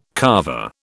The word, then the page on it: Carver: British English